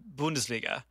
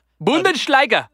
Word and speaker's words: Bundesliga: The Bundesliga. You got to pronounce it, man. – Bundesliga. – Bundesliga.